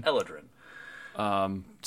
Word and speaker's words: Eladrin: Is that how you would pronounce that? – I would call that Eladrin. – Eladrin.